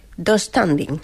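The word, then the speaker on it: döstädning: Yo lo pronuncio... Dos standing. – Sí, tengo esa silla de IKEA. Sí, se pronuncia así.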